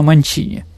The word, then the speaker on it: Манчини: Но во французском языке, дорогие друзья, ударение всегда на последнюю гласную. Но она была итальянка, поэтому на Манчини.